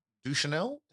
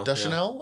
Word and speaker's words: Deschanel: And then the other thing, as much as I enjoy Zoe Deschanel. – Deschanel. – Yeah. I never say it right.